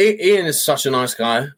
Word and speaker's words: Eion: It's E-I-O-N, but it's Ian or Ian. – Ian. – I had it right the first time.